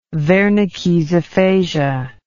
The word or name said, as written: wernickes aphasia